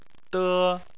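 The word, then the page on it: de: tone 1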